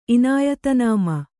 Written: ಇನಾಯತನಾಮ

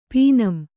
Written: ಪೀನಂ

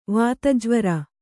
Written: ವಾತಜ್ವರ

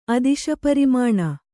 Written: ಅದಿಶಪರಿಮಾಣ

